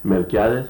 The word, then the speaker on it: Melquiades: Melquiades. You know how to spell it? I spell it for you. – M-E-L-Q-U-I-A-D-E-S.